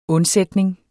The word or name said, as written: undsætning